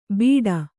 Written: ಬೀಡಾ

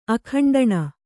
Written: ಅಖಂಡಣ

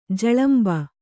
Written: ಝಳಂಬ

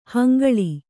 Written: ಹಂಗಳಿ

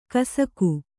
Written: ಕಸಕು

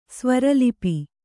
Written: ಸ್ವೈರಾಲಾಪ

